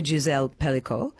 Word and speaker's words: Gisèle Pelicot: This as well, I'm just wanting to know, you actually dedicated it to to Gisèle Pelicot.